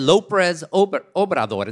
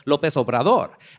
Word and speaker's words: López Obrador: And then when she tried to say it during the debate, she mispronounced it. She said López Obrador instead of López Obrador.